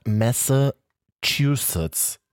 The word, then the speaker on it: Massachusetts: Und zwar die Stadt Massachusetts. Boah, richtig ausgesprochen.